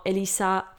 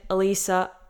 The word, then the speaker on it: Elissa: non lo so se dici il mio nome in francese tipo Elisa o in inglese Elisa o all'italiana Elissa per me è sempre uguale. Infatti, mia madre mi ha chiamato così per quello, perché è facile da pronunciare in tantissime lingue ehm, e non mi dà fastidio.